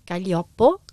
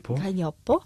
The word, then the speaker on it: Gaglioppo: Und das ist auch wieder so ein Zungenbrecher zum Aussprechen, weil schreibt man ja Gaglioppo. – Ja, das stimmt. – Gaglioppo gesprochen. – Gaglioppo.